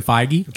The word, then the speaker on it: Feige: Kevin Feige. – Kevin Feige.